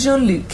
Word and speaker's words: Jean-Luc: Did I say it right? Not Jean-Luc. – Captain Picard to you, I think. – Oh, Captain, okay. Well, I was over familiar. – Is Jean-Luc, yeah.